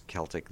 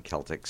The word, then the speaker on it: Celtic: And by the way, for anybody who's wondering, yes, it is Celtic. That is the proper pronunciation. I suppose if you're talking about the basketball team, people would probably look at you funny if you called them the Boston Celtics, so maybe it's better to call them the Celtics, but Celtic is the right pronunciation.